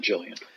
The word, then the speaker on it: Gillian: He's been in a bunch of great stuff, including Westworld and Gillian Jacobs, or Jillian Jacobs, however she pronounces it. – I believe she pronounces it Jillian.